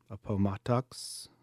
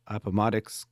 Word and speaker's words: Appomattox: Lee, who was one of the main generals of the Confederate Army, actually surrendered on April 9th, 1865, in Appomattox. I'm saying that wrong. Appomattox County, Virginia.